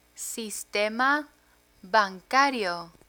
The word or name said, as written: sistema bancario